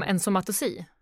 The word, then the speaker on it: ensomatosi: men när du pratade om ensomatosi. – Ja, precis. Just det, det lilla ordet. Ja. – Det lilla ordet, ja. – Det lilla ordet, ja. – Som jag egentligen lärt mig uttala. Ensomatosi.